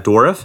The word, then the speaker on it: Dourif: However, Brad Dourif, is that how you pronounce his name? – Yep.